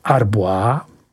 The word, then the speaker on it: Arbois: Jsou to Arbois, vyslovuj Arbois.